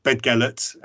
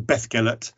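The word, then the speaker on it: Beddgelert: There's a village in Wales, town in Wales even, called Bedgellert, as we say it in England, or as I've been corrected, it's apparently it's pronounced Bethgellert, even though it's got a double D in it.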